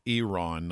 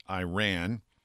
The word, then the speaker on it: Iran: he admonished him for mispronouncing the country. It's Iran, not Iran.